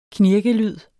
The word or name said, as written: knirkelyd